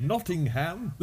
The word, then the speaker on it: Nottingham: From Nottingham. Did I say that right? – You did. Nottingham.